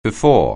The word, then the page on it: before: anglais